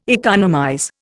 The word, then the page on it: economize: Indian